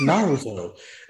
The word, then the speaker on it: Naruto: You keep saying it like that. It's not how you say it. – Naruto. You're just fucking with me. Why? – It's Naruto.